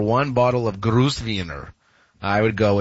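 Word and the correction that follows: Grüner Veltliner: And did I butcher the pronunciation of that Chardonnay, champagne, or whatever? Probably. – No, Gruner Veltliner.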